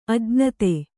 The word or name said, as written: ಅಜ್ಞಾತ